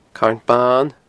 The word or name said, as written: Carn Ban